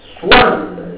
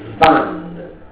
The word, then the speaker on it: Swann: Swann, il ridoppio si legge U, perché a un certo momento Proust dice Swann, non Svan, come alcuni direbbero. Swann.